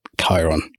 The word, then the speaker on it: Khiron: Khiron. – Khiron is, I think, the adopted pronunciation from the ancient Greek, although you can have it any way you like. – Okay. – Because it's a dead language.